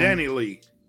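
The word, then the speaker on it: DaniLeigh: His the mother of his child is a R&B singer by the name of Danny Danny Lay. Danny Lee. Is it Danny yeah. Lee or Danny Lay? How does she pronounce it? I don't know. It's, it's um, Danny Lee.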